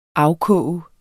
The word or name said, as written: afkoge